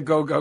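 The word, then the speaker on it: Gou: Terry, is it pronounced Go or Gao? – He says go. It's G-O-U.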